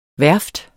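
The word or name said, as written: værft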